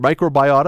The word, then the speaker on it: microbiota: You use the word, and I think we're going to use the word microbiota. Is that have I pronounced right? Microbiota. – That's right. – Microbiota.